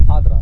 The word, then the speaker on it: Adra: Cuvântul Adra, limba arabă, înseamnă Ficioara. Adra. Asta este pronunția egipteană.